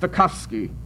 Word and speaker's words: Stokowski: I want you all to know that there is no cow in my name. It is pronounced Stokowski."